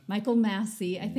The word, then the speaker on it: Michael Massey: Michael Massey.